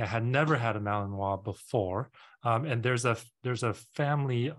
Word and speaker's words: Malinois: And so the word is a French word, so it's spelled Malinois. The French, in French, you say Malinois, right? A lot of people videos that say Malinois, which is not Malinois.